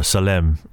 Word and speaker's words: Salem: You know, at that time, it was known as Salem or Salem, right, if you're going to pronounce it properly.